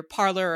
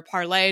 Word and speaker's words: Parler: a site called either Parler or Parlay, depending on how you pronounce it.